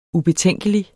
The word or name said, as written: ubetænkelig